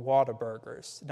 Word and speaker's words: Whataburger: They've got these restaurants out there called Whataburgers. Now, it's spelled like What a Burger, but they all say it like Whataburger, and it's like real sound together.